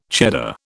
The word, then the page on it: Cheddar: British English